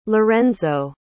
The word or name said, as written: lorenzo